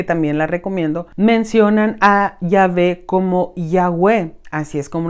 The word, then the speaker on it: Yahvé: Este tetragamatón se llama a las cuatro letras ya unidas y en su pronunciación es Yahvé.